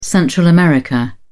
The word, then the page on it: Central America: British English